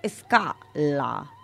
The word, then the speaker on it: Scala: Que sepáis que se, yo le diría Scala, pero me ponía entre paréntesis cómo se pronunciaba en italiano. Y se pronuncia Scala.